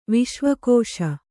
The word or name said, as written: ವಿಶ್ವಕೋಶ